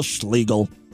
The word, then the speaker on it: Schlegel: You know, because it kind of rhymes with legal. Ah, legal Schlegel.